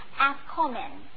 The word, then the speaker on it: afikomen: and it is the word afkomen. That's the Mississippi pronunciation, A-F-I-K-O-M-E-N.